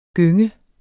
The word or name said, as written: gønge